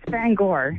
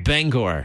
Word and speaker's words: Bangor: How do you pronounce it? – It's Bangor. – Bangor.